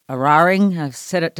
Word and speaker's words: Eraring: They've warned that the energy grid is not prepared for the closure of the Eraring. Did I say it right? – Well, that sounded pretty good thanks.